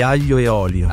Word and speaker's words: Aglio e Olio: Ich hätte gern Spaghetti Aglio e Olio. Ich weiß inzwischen, da spricht man so ein bisschen sanfter aus.